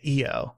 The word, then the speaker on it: Io: I mean, I know it differs, but my instinct is to say EO. – That makes more sense, but I keep hearing everyone say IO instead because I guess that's just, you know, that's just the English way of pronouncing it. – Yeah. – With a long I.